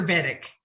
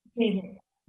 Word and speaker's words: Vedic: So the first female lineage holder of her distinguished Vedic, is it Vedic or Vedic? Do I say, is it? – Vedic. – Vedic. – You said it right.